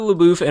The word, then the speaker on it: LaBeouf: LaBeouf. He pronounces it LaBeouf, yeah.